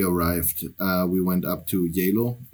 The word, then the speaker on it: Geilo: I like the German way of spelling of it because it's like written Geilo. – And that's close to how it's pronounced, Yalo. – Yalo, yeah.